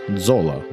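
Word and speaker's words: Zola: По-італійськи прізвище читається як Дзола.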